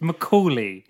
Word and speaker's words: Macaulay: How do you pronounce Macaulay Culkin? – It's Macaulay. – Macaulay? – There's no N in there. – I've been saying it wrong for years. I'm not going to stop now. – Sticking a rogue N in there.